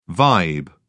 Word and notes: vibe: British English

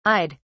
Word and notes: i'd: British English

